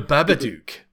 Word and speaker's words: Babadook: It's like Dabaduk, Dabadike? – The Babadook.